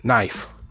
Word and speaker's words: knife: Just like knife. Spell knife, James. N-I-F-E. Knife rhymes with wife. Wife is W-I-F-E. Knife should be N-I-F-E. Nah, it's K-N. Why? Why? Because the K is silent.